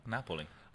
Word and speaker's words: Napoli: No, Napoli.